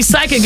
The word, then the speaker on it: psychic: So you're right, because when we see psychic, it's spelled P-S-Y, right? – I couldn't tell you. – Yes, yeah, this, yep. – So the P is silent.